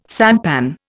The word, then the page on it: sampan: Indian